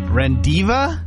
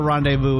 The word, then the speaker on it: rendezvous: R-E-N-D-E-Z-V-O-U-S. R-E-N-D-E-Z-V-O-U-S. – Rendiva?